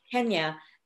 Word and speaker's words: Kenya: There's a great episode of Black AF with Kenya. Yeah, I'm saying it right. Name it. Yep. Kenya.